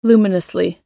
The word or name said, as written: luminously